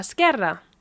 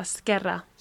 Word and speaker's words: izquierda: What about if I said al segon carré a la izquierda? – So al segon carré a la izquierda. Did I say that right? – A la izquierda.